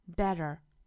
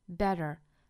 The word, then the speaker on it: better: 两个要注意的地方，一个是 t，英音的话呢是 t， t，美音的话呢是浊化了的 d， d 的发音 better。 Better，并且尾音美音的话是要卷舌，但是英音是不卷舌的。